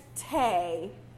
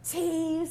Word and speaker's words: tea: So the word, like, made these two pathways across the globe from China and eventually ended up in England as Tay. That was the original pronunciation, Tay. And then it sort of, they got more excited about it. And they were like, I didn't know, Tay is nice. Doesn't tea sound more, I mean, elegante and delightful?